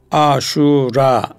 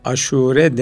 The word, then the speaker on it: Aşure: Hatiplerin çoğu aşura. Bu bize yabancı bir tabidir. Biz buna aşure günü yani 10. gün deriz. Her dilde de dilin kaidesi bir başka dilden gelen kelimeyi o dilin telaffuzuna uydurur. Onun için aşura demeye gerek yok. Aşure demek yeterli.